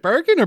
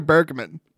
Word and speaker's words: Edgar Bergen: Is it Bergen or Bergman? – Bergen. – Oh, am I saying it wrong? – Bergen, yeah.